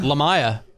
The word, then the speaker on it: Lamya: Lamia. – Come on. – Even though it's spelled Lamia. L-A-M-Y-A.